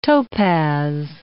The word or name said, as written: topaz